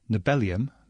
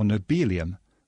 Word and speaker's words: nobelium: To begin with, there's the matter of how to pronounce its current name, nobelium, because it comes from the same root as the Nobel Prize, or nobelium, modelled on the way we say helium.